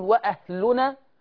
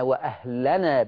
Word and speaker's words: وأهلنا: ثم أنتقل، فلما دخلوا عليه قالوا يا أيها العزيز مسنا وأهلنا، كما يخطئ البعض ويقول وأهلنا، بل مسنا وأهلنا بفتح اللام، مسنا وأهلنا الضر وجئنا ببضاعة مزجاة فأوفي لنا الكيل.